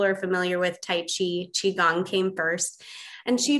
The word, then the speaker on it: Qigong: Um, Qigong is how we pronounce it.